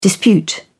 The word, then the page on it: dispute: British English